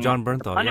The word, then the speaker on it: Bernthal: Oh, John Bernthal, yeah.